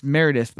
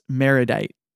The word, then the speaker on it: Meredith: Um, and it's it's Meredith, although uh, Jake definitely it's it's definitely Burgess Meredith, and he definitely calls her calls him Burgess Meredite. Yeah, which is like a weird mispronunciation.